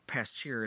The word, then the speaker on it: Pasteur: Again, Pasteur, Pasteur is kind of how it's you know spelled, but it's pronounced Pasteur.